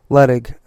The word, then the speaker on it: Luttig: Luttig, okay.